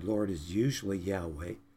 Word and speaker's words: Yahweh: It is pronounced Yahweh, more accurately, some pronounce it Jehovah.